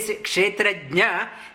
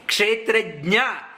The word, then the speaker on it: Kshetrajna: The name itself, we should learn how to pronounce properly. Many people, they say, they can't say Kshetrajna, they say Kshetrajna. Gna and Jna, there is a difference.